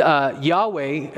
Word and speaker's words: Yahweh: And Yahweh, at least as we render the Hebrew I am into English, becomes YHWH, and we pronounce it Yahweh.